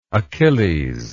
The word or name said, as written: achillean